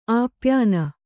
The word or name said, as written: ಆಪ್ಯಾನ